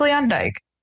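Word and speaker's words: Luyendyk: How do you pronounce Ari's last name? – Leanne Dyke. Now I'm confused. – Leanne Womack. – Leanne Dyke Jr. Okay. – It's Leanne Dyke.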